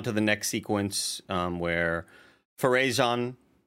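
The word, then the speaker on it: Pharazôn: Farazan, you put the acento at the final syllable.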